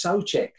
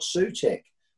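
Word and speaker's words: Souček: By the way, it's Socek, I've been told by my commentator friend. It's not Suchek. It's spelt Suchek, but over there uh, from where he's from, it's Socek.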